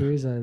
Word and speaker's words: Yakuza: Yakuza, isn't it? Surely it's Yakuza. – Yakuza, there we go.